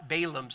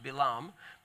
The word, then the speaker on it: Balaam: We call him Balaam sometimes. It's pronounced Balaam.